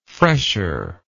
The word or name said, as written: fresher